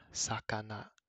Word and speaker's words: sakana: The first thing that catches your attention is a sizzling sound coming from the fish, or sakana. To spell that, it is SAKANA.